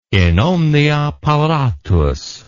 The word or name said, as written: in omnia paratus